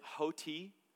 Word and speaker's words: hoti: It's a Greek word, hoti, H-O-T-I. That is how you say it.